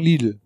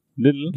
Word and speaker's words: Lidl: Ja, wir sagen Lidl. Ja. Aber ich kenne auch welche, die sagen Lidl. Little. Little. Aber das ist kein, kein Doppel-T. das ist ja wurscht.